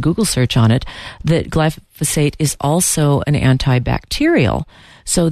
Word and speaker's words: glyphosate: We had talked last week about glyphosate, and I mispronounced it glyphosate, and excuse me for that.